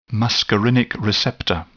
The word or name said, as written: muscarinic receptor